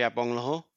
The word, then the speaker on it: Agbonlahor: Yeah, Gabby Agbonlahor said on TalkSport the other day that... – just say his name again. Where did you get that? – Gabby Agbonlahor.